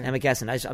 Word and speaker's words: Amagansett: Amagansett? – Yeah, yeah. Amagansett.